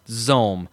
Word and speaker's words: XOHM: It's XOHM is how it's spelled. And I guess it's pronounced Zohm.